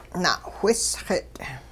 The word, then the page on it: na'whisxit': Hupa